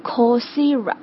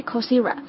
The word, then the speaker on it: Coursera: 它实际上是 course 和 era 放在一起，然后把其中的一个 e 省略掉了哈，所以就是正确的发音是 Coursera， OK， Coursera。